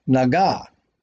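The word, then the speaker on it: Naga (נגע): In the Hebrew, the word for touch is spelled exactly the same as plague, Nun, Gimel, Ayin, read right to left. The root is the same. However, by adjusting the vowels, it is pronounced Naga.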